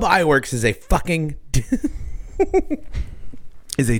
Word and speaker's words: Iwerks: UB Iwerks is spelled UBIWERKS. First name, UB, UB. Last name, Iwerks, Iwerks.